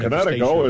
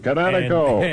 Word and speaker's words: Kinetico: Everybody knows that Connecticut is spelled K-I-N-E-T-I-C-O. – I thought the instructor said Connecticut. – You should have remembered kinetic as in kinetic energy with an O on the end. Kinetico. – Kinetico. Spell it K-I-N-E-T-I-C-O.